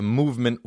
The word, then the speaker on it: MVMT: Have you heard of movement watches? If you haven't, you live under a rock. It's spelled MVMT.